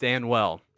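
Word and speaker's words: Danuel: It is one Daniel House of the Houston Rockets. – Danwell House. – Danwell. – Yeah. It's pronounced Danwell.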